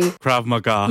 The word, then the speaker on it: Krav Maga: So, you know, we have Krav Maga. Am I saying that right? The Israeli Krav Maga.